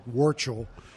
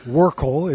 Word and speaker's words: Warchol: Actually, I pronounce it Warchol. – Warchol. – But other people pronounce it correctly, which is like Vortigol or Warchol is actually closer. – With the hard C. – With the hard C, but my family at Ellis Island apparently went with the Warchol.